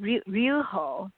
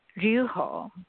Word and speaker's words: Ryuho: Actually, it's very difficult. Ryuho. Ryuho. – Oh, Ryuho. Ryuho. – Yeah, Ryuho. Yeah. So it's almost like you're not pronouncing Y.